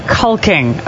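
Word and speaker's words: caulking: Doing some cocking. – It's actually pronounced culking, okay? – Culking.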